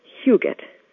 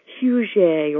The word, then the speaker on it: Huguet: It is pronounced just the way it is spelled, Huguet, but people try to make it fancy, Huguet or even Huguet, but it's just plain Huguet, and I am just plain Jennifer LaRue Huguet.